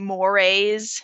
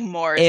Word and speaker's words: mores: And actually, D is pronounced, it's kind of funny, it's pronounced like mores. – Oh, mores. Okay. – Yeah. Looks like Morse.